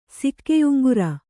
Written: ಸಿಕ್ಕೆಯುಂಗುರ

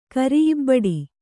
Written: ಕರಿಇಬ್ಬಡಿ

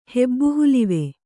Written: ಹೆಬ್ಬುಹುಲುವೆ

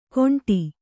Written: ಕೊಂಡಿ

